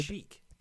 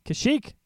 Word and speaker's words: Kashyyyk: Kashik. – Kashik.